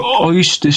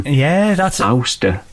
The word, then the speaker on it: Ouster: So Oyster Stew, that's weird, isn't it? Because it's spelled O-U-S-T-E-R. So it's like ouster. – Oh, yeah. I just said oyster.